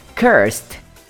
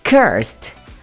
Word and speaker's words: cursed: E se você colocar um D no finalzinho dessa palavra, vira o adjetivo amaldiçoado. Saca a sua pronúncia? Cursed. Cursed. O que pouquíssimas pessoas sabem sobre essa tal palavra curse é que ela também pode ser traduzida por praga, blasfêmia e menstruação.